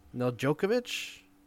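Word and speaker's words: Nedeljković: Alex Nedeljkovic, That sounds right.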